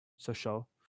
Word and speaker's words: Sochaux: Sochaux. I don't know if is that how you pronounce that French club? Yeah. – Yeah. – In Ligue 2. Sochaux.